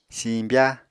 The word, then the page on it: siñbya: Apatani